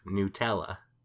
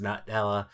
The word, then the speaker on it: Nutella: Nutella.